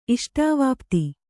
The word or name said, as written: ಇಷ್ಟಾವಾಪ್ತಿ